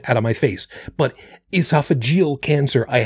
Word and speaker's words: esophageal: Esophageal cancer? – Yeah.